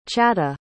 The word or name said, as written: chata